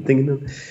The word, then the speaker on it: Dingenen: So my Twitter is just actually my name, at Bart Dingenen. I'm speaking Dutch, so that's what Dutch. how you pronounce my name? Dingenen.